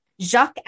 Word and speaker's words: Jacques: J-A-C-Q-U-E. Yeah. So I'm going to guess it's Jacques. Jacques Alexander Tardy. – You did it. – I mean, the rest of the name was easy, but Jacques.